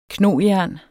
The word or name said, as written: knojern